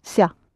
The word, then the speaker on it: ся: The ending that you add is S-я, ся, but it's pronounced usually ца, like in pizza.